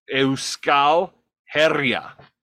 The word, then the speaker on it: Euskal Herria: The pronunciation of Basque Country is Euskal Herria.